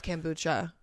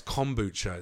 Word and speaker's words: kombucha: I love kombucha. I love juice cleansing. – Can't even say it right. It's kombucha.